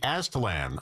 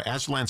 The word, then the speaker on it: Aztalan: And one of them, and I always mispronounce this place, the Aztalan. I hope I'm saying it right. Aztalan State Park. – I've heard it said that way. – Say it correctly. – Talon, I think you're closer.